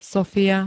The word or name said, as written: Sofia